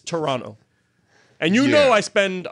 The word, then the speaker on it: Toronto: Yes, but it's not Toronto. You don't say the second T. They'll look at you and say, you're not from here. – Well, how would you pronounce it? – It's Toronto, like two N's. You don't put the second T. Ask anybody. It's Toronto.